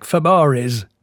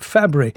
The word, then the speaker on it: Fabares: Johnny Angel, Shelley Fabre. Now, interesting about the spelling of that name, it looks like Fabares, but no, they pronounce it Fabre.